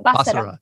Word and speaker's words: Basara: Am I saying that right? The Bicycle Conspiracy, Svetislav Basara. – Basara. Yeah.